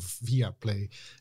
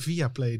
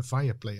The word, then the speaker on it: Viaplay: En daar wordt het eh, volgens mij als fireplay eh, uitgesproken. – Nee, niet. Nee? nee. Oh.